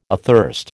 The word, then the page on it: athirst: American English